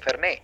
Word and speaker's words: Fernet: porque le quitan la T, se escribe fernet, pero los argentinos luego no dicen fernet, dicen fernet.